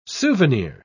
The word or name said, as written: souvenir